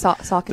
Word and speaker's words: Saucony: Is it Saucony? – Yes, Saucony.